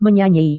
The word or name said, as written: menyanyi